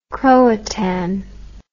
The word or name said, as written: croatan